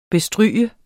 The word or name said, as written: bestryge